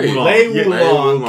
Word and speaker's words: Lei Wulong: if Lei Wulong used guns and went kind of rogue. – You said it right. Lei Wulong can use guns.